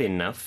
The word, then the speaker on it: enough: Do we have enough firewood? – 빈칸에는? – Enough, E-N-O-U-G-H. – 그쵸? 그래서 enough. GH로 끝나지만 요거는 F 발음이다라고도 말씀드렸고요.